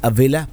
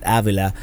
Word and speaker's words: Avila: We went to, I've been informed, this is pronounced Avila, although also the Spanish brain in me wants to pronounce it Avila.